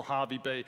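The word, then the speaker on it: Hervey Bay: So I planned out the route, and the first night we were going to stay in a place called Harvey Bay. They say Harvey Bay, but it's H-E-R.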